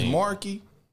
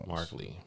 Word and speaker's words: Markle: Is Marky? – Yeah, Meghan Markle. Markley, Marky.